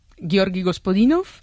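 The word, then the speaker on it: Georgi Gospodinov (Георги Господинов): Ayúdanos a pronunciar los autores. – A ver, Georgi Gospodinov, sí. Zdravka Eftimova y Vera Mutavcheva.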